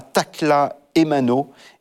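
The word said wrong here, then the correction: Takla Haymanot: Takla Aymanot et Eostateos.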